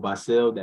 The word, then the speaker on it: Bahsil: So on Instagram, my name is Michael Bahsil. That is M-I-C-H-A-E-L. People spell it E-A-L a lot, and I hate that, but it's A-E-L, and then B-A-H-S-I-L.